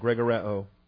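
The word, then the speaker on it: Gregoreo (γρηγορέω): Subpoint one, Gregoreo. Gregoreo, 22 New Testament uses. G-R-E, that's the long E of the eta. So gamma, rho, eta. Gamma, Doug. G-R-E-G-O-R-E-O. G-R-E-G-O-R-E-O, Gregoreo, number 1127.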